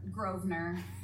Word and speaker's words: Grosvenor: Yeah, it's just Grosvenor. You don't say yes.